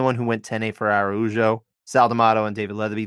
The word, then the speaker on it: Araujo: Araujo, thank you, sir.